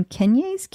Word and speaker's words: Keynes: So Red Bull Racing has invested in this Technology Campus in Milton Keynes. I wish I knew how to pronounce that. – Keynes? – Sure.